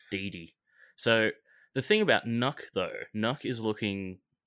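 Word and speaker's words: Nøkk: So the two operators, the one that we've received the information on so far is called Nuck. – Well done on that pronunciation, by the way.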